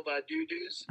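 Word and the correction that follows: Baddoo: I think it's just Badu. – Badu. Yeah, it's Badu, not Badudu's.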